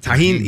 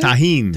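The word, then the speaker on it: Tajín: It's not a J. – I think it's tagine. I think that's what he said. – Or tahine. – Tahine, yeah. We were saying it incorrectly, and the way he said it sounded so awesome, and I thought, I'd love to hear you say it on the radio. – It's Spanish.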